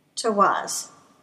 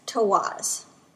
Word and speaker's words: Tiwaz: Now, the first rune we're going to be looking at from Tirs Eyet is Tawaz. It is spelled T-I-W-A-Z. That's Tawaz. Now, literally, this rune looks like an arrow pointing in an upwards direction.